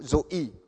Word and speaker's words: zoe: Okay, then there's another word that is used in the Bible for life, and this is the Greek word zoe, Z-O with a long E. Okay, so zoe.